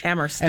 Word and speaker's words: Amherst: The locals pronounce it without the H. – Amherst. – And the funny thing about Amherst, which is such a progressive town and is always at the cutting edge of different progressive ideas, the slogan for the town is jokingly Amherst, where only the H is silent.